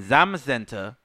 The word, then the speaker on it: Zamazenta: So legendary Pokemon Zacian and Zamazenta, which I don't know how the fuck to pronounce this one. – Zamazenta. You said it right.